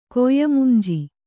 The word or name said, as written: ಕೊಯ್ಯಮುಂಜಿ